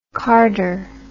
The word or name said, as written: carder